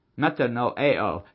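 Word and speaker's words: Metanoeo (μετανοέω): The word in Greek is metaneo. It means to change your mind. Metanoeo. It's been a while since I did my Greek pronunciations.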